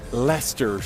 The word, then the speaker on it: Leicester: we'd probably pronounce it Leicester Square, but it's actually Leicester Square if you're a Brit.